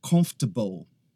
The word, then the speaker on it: comfortable: O que acontece é que algumas pessoas acabam pronunciando todas as sílabas. Comfortable. Comfortable. E isso não acontece. É simplesmente comfortable. Comfortable, tá?